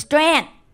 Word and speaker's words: Tran: My name is Tran.